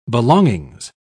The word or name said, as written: belongings